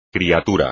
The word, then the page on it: criatura: espagnol